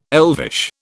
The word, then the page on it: elvish: British English